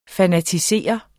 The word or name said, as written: fanatisere